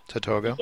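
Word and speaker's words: Tatogga: Tell us about that Tatoga, or how do you pronounce the project? – Tatoga. Yeah. Tatoga, yeah.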